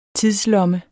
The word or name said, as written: tidslomme